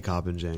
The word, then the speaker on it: Koppenjan: I just say Koppenjan because that's what I've been saying my whole life.